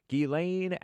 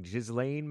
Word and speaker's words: Ghislaine: Ghislaine Maxwell, looks like Ghislaine Maxwell, but I think it's pronounced Ghislaine Maxwell, jailed for 20 years, or imprisoned for 20 years.